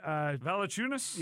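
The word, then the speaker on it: Valančiūnas: Valanciunas? – Yes, thank you.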